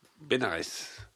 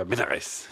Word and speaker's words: Bénarès: Elle passe 9 mois à étudier le sanskrit à Bénar. – Bénarès, puisque c'est le dans le sud. – On prononce toutes les lettres, monsieur Bénarès.